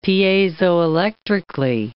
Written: piezoelectrically

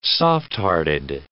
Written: softhearted

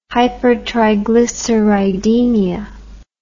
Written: hypertriglyceridemia

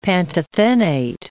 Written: pantothenate

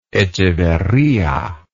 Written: echeverria

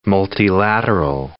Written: multilateral

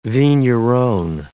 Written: vigneron

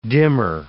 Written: dimmer

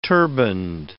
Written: turbanned

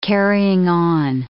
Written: carrying-on

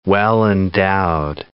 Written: well-endowed

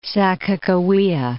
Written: Sakakawea, Lake

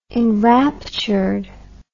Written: enrapturedly

